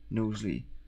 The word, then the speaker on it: Knowsley: It's spelled Knowlesy, as in no sleigh. – No sleigh, as in yeah, S-L-A-Y. – Sorry, K-N-O-W-S-L-E-Y. Knowlesy.